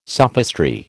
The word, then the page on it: sophistry: American English